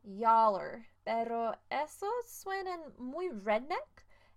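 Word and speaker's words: y'all're: Entonces, no recomiendo que uses esas frases muchas veces, especialmente yaller. Yaller es una combinación de tres palabras y se escribe como Y apóstrofo A L L apóstrofo R E.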